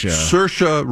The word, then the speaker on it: Saoirse: Ronan Sarisa, that pronounce her first name? – Saoirse Ronan, yeah.